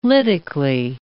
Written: lytically

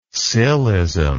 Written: scillism

